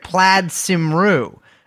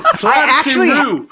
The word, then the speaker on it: Plaid Cymru: Well, I was actually sent by the CIA to infiltrate Plaid Cymru. – Oh my God, man. That is the best pronunciation I've ever heard in my life of that fucking... Plaid Cymru. Wow, man. Plaid Cymru, right? – Yeah.